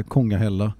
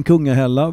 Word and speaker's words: Kungahälla: Idag är det ju Kungälv, men Kungahälla eller Kongahälla uttalas med norskt uttal mer med O, tror jag, och Kongahälla, svenskt med U. Mm. Men Kungahälla var ju en,